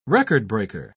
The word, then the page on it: record-breaker: British English